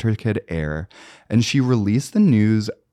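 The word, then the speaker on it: Aire: So Kylie released the name of her baby boy, Air with an E, pronounced Air, because people were like, is it pronounced Airy?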